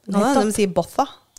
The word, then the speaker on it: Botha: Jag vet skriva B O T H A.